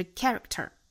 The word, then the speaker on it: character: 注意这个 k 不要发的过重哈，不要发了加元音的那个 k，不是 caricature，而是 character, character.